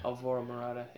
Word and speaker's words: Alvaro Morata: Did Alvarado Morata... How do you say Alvaro. – Can you just say it? You're the Italian. – Alvaro Morata.